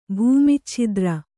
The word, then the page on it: ಭೂಮಿಚ್ಛಿದ್ರ: kannada